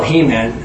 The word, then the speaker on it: Haman: But because if you Google this guy, Haman, it's spelled Haman. There used to be a cartoon, easy way for me to remember. I never forgot his name. Because He-Man was like a Conan.